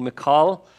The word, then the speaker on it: Michal: We left it last time with David taking Michal. I'm taking Paul's pronunciation of that as mine, and he took Saul's daughter as his wife.